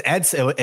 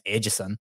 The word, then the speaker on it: Edson: I mean, it's Edson. If he says it in South Florida, it's Edson Barboza, right? If he goes to the coffee shop, if he goes to Starbucks, he's not saying my name's Edgison.